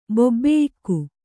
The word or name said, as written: ಬೊಬ್ಬೆಯಿಕ್ಕು